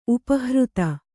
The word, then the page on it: ಉಪಹೃತ: Kannada